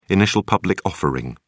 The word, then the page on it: initial public offering: British English